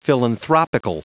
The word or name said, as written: philanthropical